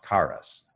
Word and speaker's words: charis: But this issue of grace, if we transliterate the Greek word into English, it comes into English as C-H-A-R-I-S. You may look at that and you may think that it's pronounced charis. Well, it's not. It's got that charis sort of thing. And I know Mary is probably rolling her eyes at me trying to pronounce that. Trying to get the initial sound right, and then trying to roll my Greek R, but that's what the word is.